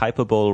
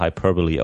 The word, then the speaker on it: hyperbole: Fängt im Grunde der erste Satz schon, think hyperbole rhymes with superbowl und das wird aber hyperbole ausgesprochen, was komisch ist, aber ist nur mal so.